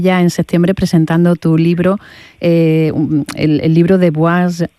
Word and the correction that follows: Boise: lo digo bien, a Madrid y vuelta. – Es Boise. – Ah, Boise, es verdad. Boise de Idaho, ¿no? – Es inglés, eso es.